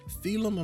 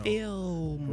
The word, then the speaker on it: Feelm: but it's not Thelum because it is spelled F-E-E-L-M. You would not understand how many times people be like, Thelum. I'm like, no, film. It's a play on film.